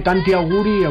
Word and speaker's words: tanti auguri: ¿Cómo se dice tanti auguri o cómo se dice en italiano lo de felicidades? – Tanti auguri.